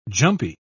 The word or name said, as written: jumpy